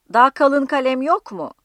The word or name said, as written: yok